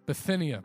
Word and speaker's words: Bithynia: If you see this idea of Bithynia, sorry, I can't pronounce that right. Bithynia. Am I saying that right? Bithynia? Yeah. Was it? Bithynia, praise God.